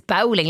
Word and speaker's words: bowling: Because down in West Cork, it is bowling, like rhyming with howling.